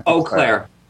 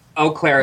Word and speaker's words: Eau Claire: Eau Claire. – Eau Claire. – Eau Claire.